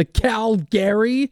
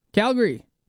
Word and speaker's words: Calgary: The Calgary Stampede. Why is he taking a break in between the two? Calgary.